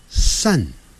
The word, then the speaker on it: sun: 太阳叫 sun，哎，不要念成 sun，不是念 sun 啊。你看，有点像一二三的三 sun。